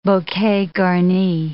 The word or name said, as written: bouquet garni